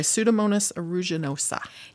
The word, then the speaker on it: Pseudomonas aeruginosa: And we studied the bacteria Pseudomonas aeruginosa. – I was hoping you'd pronounce that. Okay, Pseudomonas aeruginosa.